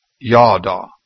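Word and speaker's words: Yadah (ידע): The original Hebrew word in the Bible is pronounced Yadah.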